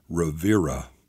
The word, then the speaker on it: Riviera: True, it has a few million less people, no rivers, no film festival, no world-class beaches, but it's not pronounced the same either. It is pronounced Rivera. So the R is still there, but we get rid of that annoying detour caused by the unwanted I and replace it with an E to complement the other E to streamline our way to the R.